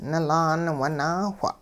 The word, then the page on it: nilaan winaawh: hupa